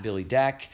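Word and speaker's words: Billy Dec: It's just at Billy Deck.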